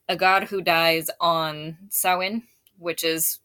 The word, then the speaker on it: Samhain: I've heard Samhain. – Is what it looks like when it's spelled, yeah. But it's like an Irish word and it's pronounced Samhain. – Samhain, okay.